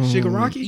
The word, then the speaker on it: Shigaraki: Oh, Shigaraki? – Yeah, that.